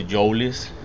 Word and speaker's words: Joelis: Sometimes I even guess myself like how I really you know say my name, but my mom always say Joelis. – Yeah. – So Joelis. – Joelis. – So almost like Joe Less. – Joe Less, yeah.